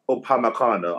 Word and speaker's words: Upamecano: How he starts Upamecano, I'm sorry.